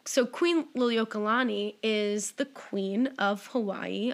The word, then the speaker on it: Liliuokalani: The queen at the time is Queen Liliuokalani.